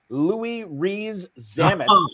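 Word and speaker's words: Louis Rees-Zammit: I do know my Packers were interested in Louis Riez-Zamit or Louis Riez-Zamit. Am I pronouncing this name right? – Yeah, Farrell? Yeah. You're in the ballpark, which is all you need.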